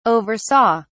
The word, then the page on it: oversaw: British English